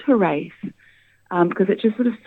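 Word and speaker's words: Hiraeth: Am I saying this correctly? It's pronounced Hirath? – Hirath. Yeah, is the way.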